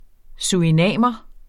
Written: surinamer

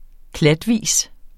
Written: klatvis

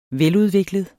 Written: veludviklet

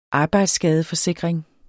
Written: arbejdsskadeforsikring

